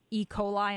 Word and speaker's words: E. coli: How common is it to be infected with E. coli? Am I even saying that correctly? – You are, yes.